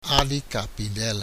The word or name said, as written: Ade capidela